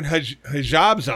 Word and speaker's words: hijab: No, it's not Habib. Hijab? It's like hijab. – Hijab? – It's H-I-J-A-B, I think.